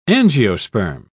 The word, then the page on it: angiosperm: British English